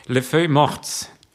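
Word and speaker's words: Les Feuilles Mortes: Le Feuille Mortes, Richtig ausgesprochen? – Ja.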